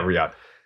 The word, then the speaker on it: Riyadh: And I could tell you when, like, the one time I wrestled over there, that place, uh, is it, how's it pronounced? Is it Riyadh? Yeah, Riyadh it, we did two shows over there.